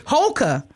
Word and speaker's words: Houlka: Houlka.